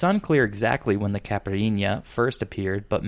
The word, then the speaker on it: Caipirinha: Caipirinha.